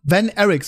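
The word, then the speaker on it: Von Erich: Geht um die legendäre von Van Erick, so Van wird das auch schon ausgesprochen, Van Erick-Familie.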